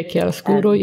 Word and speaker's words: Chiaroscuro: And I love the, it's called Chiaroscuro. – Chiaroscuro, yeah.